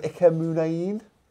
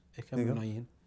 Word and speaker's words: Muniain: Bit of Ike Muniain. – I'm still learning not to say it. – Well, that's how you say it.